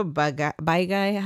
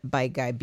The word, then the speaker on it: Baigai: The Shiro Baigai. How do you say that? B-A-I-G-A-I. Baigai.